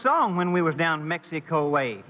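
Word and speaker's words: México: I know for a fact that you two have never been in Mexico. Why, you don't even know how to pronounce it right. The correct Spanish pronunciation is Mexico. Mexico.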